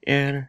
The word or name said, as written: er